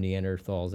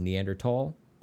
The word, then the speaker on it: Neanderthal: Real quick, I've heard that the proper way to pronounce it is Neanderthal, but that sounds like I've got a speech impediment. – Yeah. – I'm just going to say Neanderthal. – Yeah. – That's how it's written.